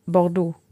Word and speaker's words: Bordeaux: Wie Bordeaux. – Ja. – Ja, Wix. Ja, Wix, ja. E-A-U-X wird O ausgesprochen.